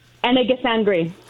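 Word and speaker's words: Anandasangaree: Anadisangri.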